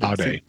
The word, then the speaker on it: aude: Aude. – Aude, sapere aude. Oh yeah, you got to pronounce that last E in Latin.